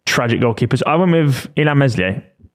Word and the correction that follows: Meslier: I think it's pronounced Melier.